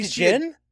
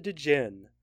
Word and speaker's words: djinn: What the fuck is a djinn? – Yeah. – Yeah. I know the D's not actually pronounced, but...